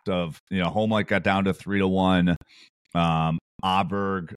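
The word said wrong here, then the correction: Åberg: I believe it's how you pronounce it. Oberg, thank you. Yeah, that's right.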